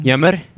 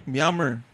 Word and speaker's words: Myanmar: Yammer? – Myanmar.